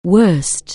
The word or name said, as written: bad